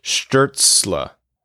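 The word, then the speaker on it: Stützle: Pesky Prussian says, was going to mention, based on German pronunciation, Tim Stutzel's last name is pronounced more like Sturzla.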